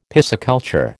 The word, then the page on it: pisciculture: American English